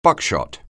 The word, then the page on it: buckshot: anglais